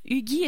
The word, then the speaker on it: Hugi: Alors, j'ai au bout du fil avec moi Anita Hugui. Est-ce que je prononce bien votre nom, Anita? – Absolument. Hugui comme Huguenot.